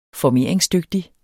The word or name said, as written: FORMERINGSDYGTIG